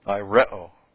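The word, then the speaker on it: aireo (αἴρω): It's to lift up aireo. Okay? A-I-R-E, long O.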